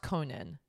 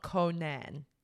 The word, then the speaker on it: Conan: It's pronounced Conan, not Conan.